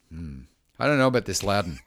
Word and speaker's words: Loudon: How do you spell it? – L-O-U-D-O-N. – O-M? – N. – Oh, I think Louden. – Louden.